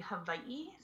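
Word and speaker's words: Hawaii (Hawaiʻi): So if you were to pronounce it correctly, it would actually be Hawaii. So the W is pronounced as a V, so it's Hawaii. But for short, people just say Hawaii.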